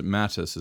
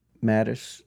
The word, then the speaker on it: Mattis: Yeah, and I I pronounced it Mathis. Is it Mathis? – Mattis. – Mattis, okay. Just say Mad Dog.